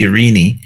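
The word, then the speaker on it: irene (εἰρήνη): The word in the Greek for peace is pronounced irene, which means to bind together.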